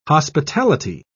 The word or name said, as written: hospitality